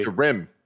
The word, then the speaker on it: Skyrim: I think the emphasis is on the rim part. – Okay. – Skyrim.